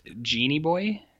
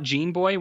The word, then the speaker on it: Genie Boy: Uh, and in its letterboxd description, it's it says pronounced Genie Boy. Huh. So, uh, so not Gene even though, Boy. – Not Gene Boy, which sounds better,